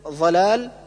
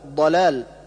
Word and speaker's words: ضلال: كالنطق بالضاد ضاء، مثل ظلال، والصواب ضلال، ومثل ولا الضالين، والصواب ولا الضالين.